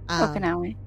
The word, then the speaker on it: Okinawa: So in Okinawa, sorry, am I saying that right? – Okinawa, yeah.